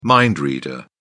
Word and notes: mind reader: British English

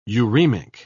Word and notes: uremic: British English

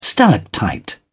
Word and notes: stalactite: British English

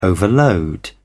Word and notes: overload: British English

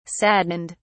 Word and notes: saddened: British English

